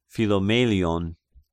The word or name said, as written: Philomelion